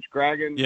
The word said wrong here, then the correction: Spraggins: Yeah, Spraggins. Yeah.